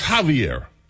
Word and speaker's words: Javier: Or even when I do know when it's Javier, I keep calling him Xavier. Because that's X-A-V-E-R, Xavier. But it's Javier.